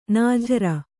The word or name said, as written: ನಾಜರು